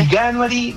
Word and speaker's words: Veganuary: ¿Cómo se dice, por favor? Sálvame. – Mira, la verdad... ¿Cómo se? Veganuary, Veganuary.